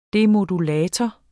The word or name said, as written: demodulator